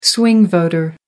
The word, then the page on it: swing voter: American English